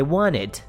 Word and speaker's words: wanted: Ah, vou tirar esse T porque na verdade a pronúncia perfeitinha é assim, ó. I wanted. Ok? Mas saiba que é o wanted que se escreve, mas a gente não pronuncia o T.